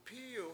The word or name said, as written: pIIy1w